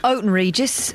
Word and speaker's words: Houghton Regis: If there's anybody listening in Houghton Regis, I've sent a message on Facebook. – Or Houghton Regis, as the locals say.